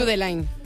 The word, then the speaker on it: Judeline: Pues entonces Judeline. Judeline. Si es de Barbate, Judeline.